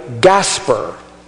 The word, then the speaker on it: Gaspar: And so one of them is supposed to have been named Melkon, the other Belshazzar, and the other one Gaspar, like Casper, without the C and not a friendly ghost, but pronounced that way.